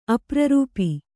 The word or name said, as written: ಅಪ್ರರೂಪಿ